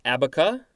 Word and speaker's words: abaca: Next entry is, I'm thinking it's pronounced abaca. I believe that is correct. Noun from around 1805. Looks like Spanish, abaca, with an accent on the last letter.